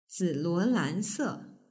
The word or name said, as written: ziluolan